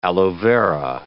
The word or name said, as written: aloe vera